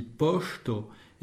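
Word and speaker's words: poŝto: Ça se dit posto et ça s'écrit P-O-S, chapeau, T-O. Si on oublie le chapeau, on pense à poste, qui veut dire après.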